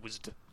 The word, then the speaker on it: WASD: I just call it WASD. I just compress it into one like a syllable word.